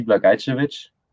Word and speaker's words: Blagajcevic: And yeah, my Twitter ad is at D Blagajcevic. That's how you pronounce my last name. So just the D and Blagajcevic, because my full name would be too long for Twitter.